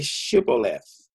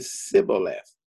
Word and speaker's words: Shibboleth: And if he said, "No," then they would say to him, "Then say Shibboleth." And he would say Sibboleth, where he could not pronounce it right.